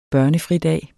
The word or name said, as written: børnefridag